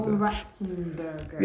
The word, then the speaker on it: John Ratzenberger: John Ratzenberger.